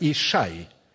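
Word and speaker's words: Ишай (ישי): Его имя на иврите произносится как Ишай и означает «Яхве есть сущий».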